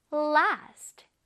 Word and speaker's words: last: Last. 지속되다, 오래가다 라는 뜻입니다. 왜 음식을 냉장고에 보관을 하면 조금 더 오래 가게 도와주지요? 자, 우리 한번 발음해 볼게요. Last. Last. 자, 이 last가 아니라 last, 길게 last.